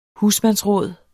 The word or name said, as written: husmandsråd